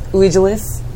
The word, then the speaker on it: Vigilus: Also, it's Vigilus. The V is pronounced like a U.